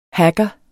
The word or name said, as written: hacker